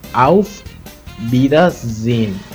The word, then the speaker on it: Auf Wiedersehen: Y aquí te dejo la pronunciación que es Auf Wiedersehen.